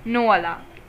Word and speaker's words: Noelle: So it's about, this whole movie is all about Nole. I think I'm pronouncing the word correctly, the name Noelle. – Yeah, Noelle. – Noelle. I thought there was an A in pronunciation. Noelle.